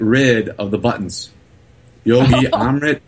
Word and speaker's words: Amrit: It's Amrit, A-M-R-I-T, uh-huh. And then his last name is D-E-S-A-I. It's Yogi Amrit Desai.